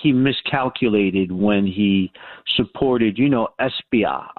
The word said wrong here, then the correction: Espaillat: I always pronounce his name wrong. The congressman, the Dominican American. – Congressman Espaillat.